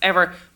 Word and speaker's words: Emer: But so the Shergling and Cullen text, the original early Irish text, you've got a lot of speech from this character whose name actually would be Ever, E-M-E-R, would have been pronounced Ever, which is now my name, Emer, which is also wrong, which is nice and pleasant.